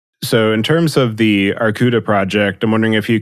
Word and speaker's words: Arkouda: Arcuda, which is the Greek word for bear, it's spelled A-R-K-O-U-D-A, is an open source framework for big data, and it's available from GitHub, so anyone can check it out.